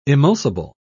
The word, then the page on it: emulsible: British English